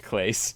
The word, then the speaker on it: Clase: Yeah, but still, like, you want the piece you got for Kluber was Emmanuel Clase, and I know we call him Clase a lot on here, but I've since learned it's actually pronounced Clase.